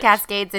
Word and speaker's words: Cascades: Is it just Cascades? – Well, I thought that there was like a French pronunciation. – Oh my gosh. It's too much. – I know much. – It's Cascades in English.